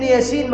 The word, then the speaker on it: يس: بعدين ياسين مكتوبه ازاي؟ ياسين لو قراتها تقرا ايه؟ يس، لكن بتنطق باسم الحرف يا، اسم الحرف يا سين، مش بمنطوق الحرف.